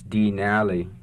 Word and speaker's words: Denali: In Koyukon, it's Denali. If Robert were pronouncing it from his native village of Minto, it would be pronounced slightly differently.